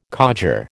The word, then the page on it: codger: American English